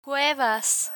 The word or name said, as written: cuevas